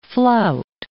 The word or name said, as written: flout